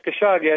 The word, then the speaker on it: Khashoggi: Is that how you say it? Anyway, Daily Caller reporting that. Say again. – Yeah, I think it's pronounced Khashoggi. I think you're right there.